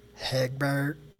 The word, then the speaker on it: Hegbert: Hegbert. – Can you spell that for me? – H-E-G-B-E-R-T.